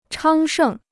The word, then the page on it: 昌盛: chāng shèng